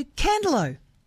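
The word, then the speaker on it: Candelo: Candalow, a place I always pronounce correctly.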